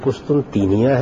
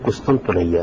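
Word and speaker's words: قسطنطنیہ: جی، اس کے دو تلفظ ہیں، ایک قسطن تینیا ہے، ایک قسطن تنیا ہے. دونوں تلفظ بالکل ٹھیک ہیں، لیکن قسطن تنیا اس کا کوئی تلفظ نہیں ہے جو عام طور پر اختیار کیا جاتا ہے.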